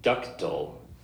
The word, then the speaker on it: ductile: Ductal. – Ductal. It's spelled D-U-C-T-I-L-E. When I first seen it, I was pronouncing it ductile, only because of the way, you know, it's spelled. But it is ductal.